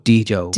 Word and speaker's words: Djo: Let's go with Djo. Because his name is Joe, right? – It's Joe Keery.